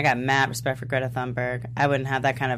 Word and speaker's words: Thunberg: Donna Levesser, and I'm sorry if I mispronounced her last name, says, Doctor Who played by Greta Thunberg. – Thunberg.